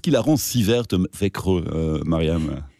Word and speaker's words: Växjö: Växjö, c'est son nom, je pense.